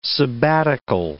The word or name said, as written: sabbatical